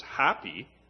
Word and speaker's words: Hapi: Or was the first plague also a judgment against the Egyptian god, and I think the pronunciation is Happy, H A P I, who was known as the spirit of the Nile?